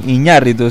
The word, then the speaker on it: Iñárritu: Creo que Iñárritu, sí.